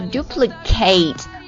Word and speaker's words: duplicate: 我们分别来看一下例句，动词的时候它的读音是 duplicate，发 A 音呢、啊、字母 A 发 A 音， duplicate， duplicate 重复或者再做一次。